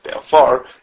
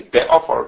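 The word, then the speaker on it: be'afar (בעפר): If the stress is on the ois groinius, then the preceding letter gets a kamatz, and if it's like in our case with a stress is on the end of the word be'afar, then the prefix of the shayrish gets a segel. Like in our case be'afar, be'afar, the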